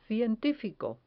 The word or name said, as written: cientifico